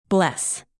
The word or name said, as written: bless